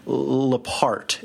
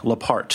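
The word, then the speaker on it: Leaphart: Her name is Paulette, and I think her last name is pronounced Lepart. It's L-E-A-P-H-A-R-T, Lepart.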